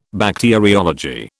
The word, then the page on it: Bacteriology: British English